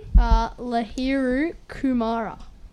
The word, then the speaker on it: Lahiru Kumara: Yeah, so what are your thoughts on Lahiru Kumara? – Pretty good. – Yeah. – Yeah. How do you pronounce his name again? – Lahiru Kumara.